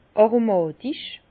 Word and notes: aromàtisch: Bas-Rhin; Herrlisheim

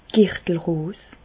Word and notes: d' Girtelross: Bas Rhin; Reichshoffen

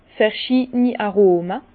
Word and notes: verschiideni Aroma: Bas-Rhin; Herrlisheim

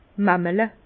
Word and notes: mamelle: Bas Rhin; Herrlisheim

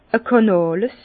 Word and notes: e Kànàles: Bas Rhin; Herrlisheim

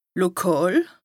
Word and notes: lokàl: Bas-Rhin; Strasbourg

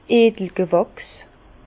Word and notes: d'edli G'wàchs: Bas-Rhin; Herrlisheim

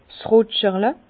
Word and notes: 's Rutscherle: Bas-Rhin; Herrlisheim

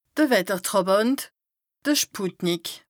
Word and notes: de Wettertràbànt, de Sputnik: Bas Rhin; Strasbourg

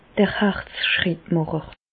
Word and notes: d'r Herzschrittmàcher: Bas-Rhin; Reichshoffen

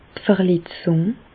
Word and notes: d' Verletzùng: Bas-Rhin; Reichshoffen

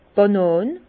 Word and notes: d' Banan: Alsatian; Bas-Rhin; Herrlisheim

